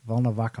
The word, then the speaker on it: Volnovakha: Hang on. Volkhov? Volknov? Volnokhava. Volnovakha Hospital. Okay.